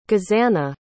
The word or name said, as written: gezana